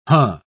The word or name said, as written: huh